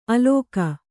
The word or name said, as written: ಆಲೋಕ